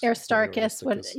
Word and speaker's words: Aristarchus: Aristarchus, is that how you say his name? – Aristarchus.